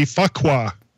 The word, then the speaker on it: Fuqua: Her last name rhymes with bouquet. – Oh, so that would be Fuqua. – Yeah, because that sounds just like bouquet.